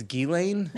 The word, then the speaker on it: Ghislaine: It's Ghislaine? – Yeah.